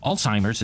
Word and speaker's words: Alzheimer's: Alzheimer's is pronounced with a T, even though there's no T in the spelling of Alzheimer's.